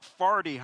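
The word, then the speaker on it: forty: If you're really from St. Louis, it's more like Farty Highway. It's kind of how they say it.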